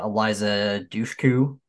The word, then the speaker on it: Eliza Dushku: Um, obviously we got uh, uh Eliza Dushku.